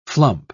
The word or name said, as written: flump